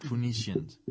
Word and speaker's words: Phoenicians: It's literally spelled like Phoenix, but Phoenicians.